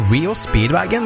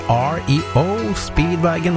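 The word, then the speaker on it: REO Speedwagon: I lang, lang tid så har jeg uttalt bandnavnet her feil, for jeg har sagt Rio Speedwagon, men det heter jo ikke det jo. Det heter R.E.O. Speedwagon, og rett skal jo være rett.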